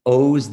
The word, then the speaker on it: Oz: Mine is O's the Mentalist. It looks like Oz, like the Wizard of. It's an unusual pronunciation, but so it's O-Z the Mentalist.